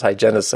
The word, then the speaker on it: hygienist: And there was actually, I remember the, and apparently I'm saying this word wrong because I pronounce hygienist as two syllables. It must be three.